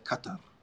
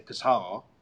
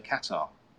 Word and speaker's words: Qatar: Like the locals say Qatar. – Okay. – I used to say Qatar like something in your nose, but now I say Qatar.